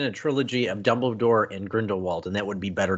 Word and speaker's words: Grindelwald: I mean, he lost two prominent roles with Captain Jack Sparrow and Grindelwald. I said that right, correct? Grindelwald? – Yep.